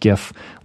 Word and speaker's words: GIF: Yes, it is pronounced GIF, like gift without a T at the end.